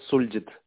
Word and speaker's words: Sulgit: My name is Suljit. – Suljit. Hey, do you spell it S-U-L-G-I-T? – Yeah, that's right, yeah.